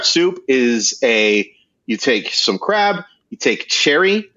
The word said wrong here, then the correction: sherry: or sherry. I always pronounce that wrong.